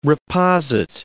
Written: reposit

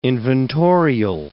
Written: inventorial

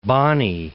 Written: Bight of Bonny

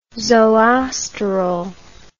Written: zoosterol

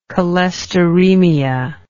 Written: cholesteremia

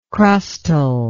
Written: crustal

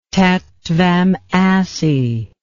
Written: tat tvam asi

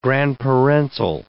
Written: grandparental

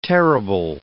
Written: terrible

